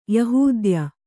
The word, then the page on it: ಯೆಹೂದ್ಯ: yehūdya